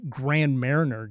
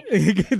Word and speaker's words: Marnier: And, you know, the other thing from the PS that I did want to mention also is last week it was Grand Mariner Day, as I like to pronounce it. – Marnier.